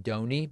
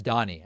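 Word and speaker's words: Donie: It looks like it would be pronounced Donny, but I believe it's Donnie. I think that's the thing. I normally say Donny, but it's actually pronounced Donnie, even though it looks like Donny.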